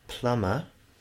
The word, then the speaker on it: plumber: For example, if you've read the word plumber, which is spelt with a B, but you pronounce it plumber, and you expect other people to say plumber, then you're going to have a really difficult time.